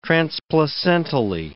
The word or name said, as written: transplacentally